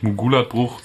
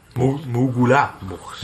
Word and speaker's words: Mugula-Bucht: Die sind ja eigentlich um die Silben, also nicht Mugulat-Bucht, sondern eher Mugula. – Mugula-Bucht. – Ja, das musst du wie zwei getrennte Wörter lesen.